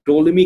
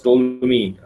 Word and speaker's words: Ptolemy: बेतलीमूस को गालिबन इंग्लिश में ना टोलमी कहते हैं, PTOLEMY, मैंने देखा था इसको, क्लॉडियस टोलमी की प्रोनाउंसिएशन यही होती है, तो उसको उर्दू में बतलीमोस का नाम दिया गया।